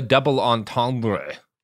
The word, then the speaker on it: double entendre: That's what we call a double entendre. You gotta. That's how you pronounce the French, Garrison.